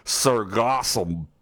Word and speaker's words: sargassum: It's a very specific type of seaweed called, let me get this correctly, sargassum. I feel like I'm about to throw up as I say it, sargassum,